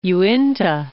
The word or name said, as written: Uinta Mountains